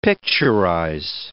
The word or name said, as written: picturize